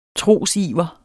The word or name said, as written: trosiver